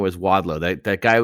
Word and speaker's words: Wadlow: Wadlow. – Is that how you say it? Yeah. Oh, there's no E, is it? It's Wadlow.